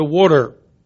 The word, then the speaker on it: water: W-O-O-D-E-R, water. I have a glass of water right here. The only people who know how to pronounce that term properly are from the tri-state area, southeastern Pennsylvania, South Jersey, and Delaware. All the rest of the world continues to go on pronouncing it the wrong way.